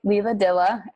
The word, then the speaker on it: Leila Dylla: Yeah, it's on my website, Leila Dylla.